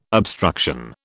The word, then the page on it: obstruction: British English